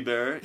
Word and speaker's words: Bieber: Bieber.